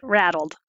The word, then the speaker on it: RADLD: So you can say rattled. R-A-D-L-D. We actually pronounce it rattled.